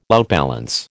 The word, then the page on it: outbalance: American English